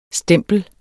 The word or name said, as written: STEMPLERNES